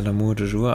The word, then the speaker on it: L'amour toujours: L'amour toujours oder so. Spricht man so aus, oder? – Ja, L'amour toujours, aber war das der Song? – Ich glaube.